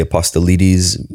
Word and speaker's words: Apostolides: I'm Greek and Italian. So actually we pronounce it Apostolides. – That's close. – Maybe in Greece they'd say Apostolides. Either way, it's all good.